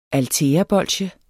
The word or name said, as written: altæabolsje